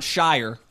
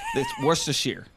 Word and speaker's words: Worcestershire: It's not Shire. This isn't The Hobbit or Lord of the Rings. It's Worcestershire.